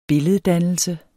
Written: billeddannelse

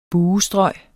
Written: buestrøg

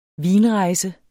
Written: vinrejse

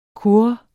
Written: kurres

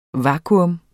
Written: vakuum

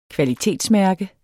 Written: kvalitetsmærke

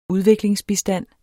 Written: udviklingsbistand

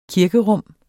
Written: kirkerum

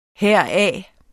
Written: heraf

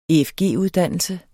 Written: efg-uddannelse

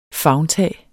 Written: favntag

